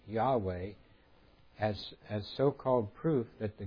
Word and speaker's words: Yahweh: seems to have been the first to suggest the pronunciation Iju, pronounced Yahweh, but it was not until the 19th century that it became generally accepted.